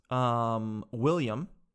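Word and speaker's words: will.i.am: And this is a gentleman, William, and it's actually pronounced Will I Am.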